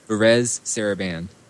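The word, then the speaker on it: Varèse Sarabande: So listen, the thing about it is that I guess, how do you pronounce this? Verise or what? – Verez Sarabande.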